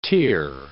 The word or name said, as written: Tyr